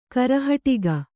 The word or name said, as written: ಕರೆಹಟ್ಟಿಗ